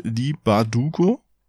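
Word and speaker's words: Leigh Bardugo: korrigier mich, wenn ich es falsch ausspreche, Lee Bardugo. – Ja, hast du gut gemacht.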